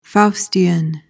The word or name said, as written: faustian